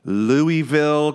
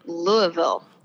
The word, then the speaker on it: Louisville: I am here in a gloomy, tornadic Waco, Texas, while in Louisville, Kentucky. – Hello. – How you doing? Did I say it wrong? – Yeah, you said it wrong. So it's, I'm learning it's Louisville. – Louisville.